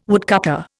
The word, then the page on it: woodcutter: Indian